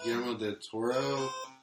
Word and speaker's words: Guillermo del Toro: Yeah, she had a simulated rape scene in a Manson video that never got released that was directed by, I think, Guillermo del Toro. That's how you pronounce his name.